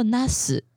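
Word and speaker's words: なす: 茄子念作 nas，嗯，那 nas 的话，哎，不能念长音哎，你如果念 nas 的话，变成护士了哦。哦，不要不要不要长音哈。 nas，呃，茄子的话，呃，是因为它跟这个成功或是做成完成某件事情的这个 nas 结成成为的成，也是一样念 nas，所以茄子呢就象征着你可以事情都。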